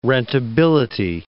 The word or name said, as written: rentability